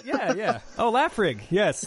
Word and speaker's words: Laphroaig: I only laugh at Laphroaig because I've mispronounced it most of my adult life, but it's Laphroaig actually. Laphroaig.